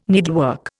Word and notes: needlework: Indian

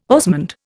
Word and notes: osmund: Indian

